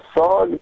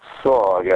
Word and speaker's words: SOG: Yes, the SOG Tiger Shark. Pronounced SOG, actually. It is an acronym. I think it stands for something like Special Operations Group, but they pronounce it SOG.